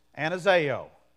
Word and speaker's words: Anazao: That phrase there, alive again, is this Greek word. Anazao. A N A Z A long O.